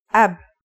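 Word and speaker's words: ab (أب): It's pronounced ab. This is the word for father in Arabic.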